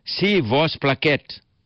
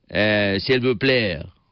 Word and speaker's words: s'il vous plaît: So she used to pronounce it "si vos plaît." Well, I don't blame her for saying it like that. No French people came to Chepi. Actually, it's pronounced s'il vous plaît. The French eat half the words, and of course we know that they eat frog legs also and funny things.